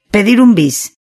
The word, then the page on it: pedir un bis: Spain Spanish